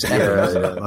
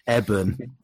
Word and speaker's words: Eben: I remember when I was watching this film, I had to make sure that I didn't call him Evan, because Evan, that's how he pronounced it. I was like, he's not. I'm saying it in the mirror. Eben. It's not Evan.